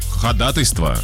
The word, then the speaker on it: ходатайство: Слово ходатайство правильно произносят в основном только юристы. Ну да, слово договор. – Ходатайство. – Да, правильно, ходатайство.